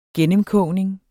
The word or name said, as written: gennemkogning